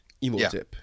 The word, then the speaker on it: Imhotep: And, you know, your boy Imhotep, or it, what, how you say it? – Imhotep. – Imhotep.